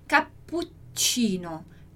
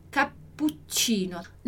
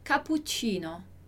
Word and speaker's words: cappuccino: A pronúncia é cappuccino, cappuccino. Não é como se fosse apenas uma letra aqui, porque se fosse uma só, seria cappuccino, ok?